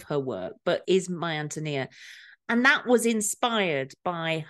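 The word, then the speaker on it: Ántonia: It's supposed to be pronounced, it's very difficult to say, but it's My Antonia. – Oh, the accent is on the A, is on the Antonia. First A, Antonia.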